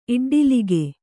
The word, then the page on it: ಇಡ್ಡಲಿಗೆ: Kannada